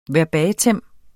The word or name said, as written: verbatim